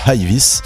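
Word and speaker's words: High Vis: Et bien Ivis, alors ça s'écrit High comme O et puis Vis comme une vis, hein, tout simplement.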